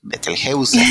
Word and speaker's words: Betelgeuse: Betelgeuse, no Betelgeuse, Betelgeuse, así se pronuncia, eh, que se llama Betelgeuse.